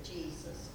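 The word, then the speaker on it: Jesús: They pronounce it Jesus. – Jesus, but it's spelt the same.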